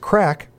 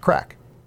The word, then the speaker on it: craic: In which the word craic, pronounced crack, refers to fun. And in Gaelic, it's spelled C-R-A-I-C, but pronounced crack.